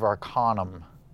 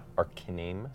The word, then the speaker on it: Arcanum: What would your characters do in the Age of Arcanum? Canum. – It's pronounced Arcanim.